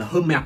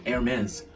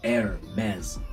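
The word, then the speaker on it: Hermès: hay đọc hãng này là hơi mệt hay là hơi mệt nhưng mà thực ra hãng này nó không đọc chữ hờ nó đọc là Hermes Hermes Hermes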